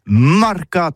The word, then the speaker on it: marcat: Eh bien, dis ça à tous, mais le mercat de l'Uni ou le mercat des rebelles, mais en gascogne toulousano, c'est prononcé sur les marcats, avec un A, marcat.